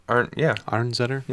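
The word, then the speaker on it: Arnezeder: Is that how you would pronounce her name? Arnsetter? – Yeah.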